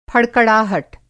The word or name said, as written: फड़कड़ाहट